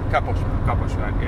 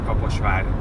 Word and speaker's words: Kaposvár: Kaposvár, yeah. It's like Kaposvár. Yeah. Var is for... That means castle.